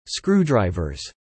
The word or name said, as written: screwdrivers